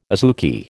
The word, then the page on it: azuki: American English